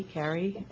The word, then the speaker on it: Carey: My name is Betty Carey, and I'm representing me.